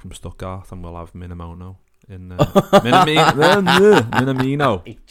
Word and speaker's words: Minamino: And the last one was obviously Takumi Minamino How did you say that? – Takumi Minamino. Nice. Not Minamoto. – Not the Minamoto.